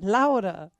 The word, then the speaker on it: Lara: L-A-R-A, right? – Yes. – Yeah. – And they were. – How do you pronounce that? – Laura.